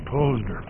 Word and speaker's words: Posner: It's a matter of a small loan, but he pronounces his name Posner.